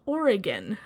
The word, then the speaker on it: Oregon: For anyone else, it's pronounced Oregon. You'd be surprised how many well-known podcasters seem to have trouble with that.